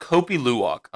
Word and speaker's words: Kopi Luwak: It's called Kopi Luwak.